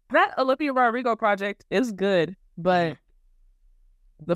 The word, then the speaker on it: Rodrigo: Rodrigo. – I pronounce Rodrigo. Hold on. Who? Because the W be coming out of nowhere. I'm telling you, after the DR, the W be coming out of nowhere.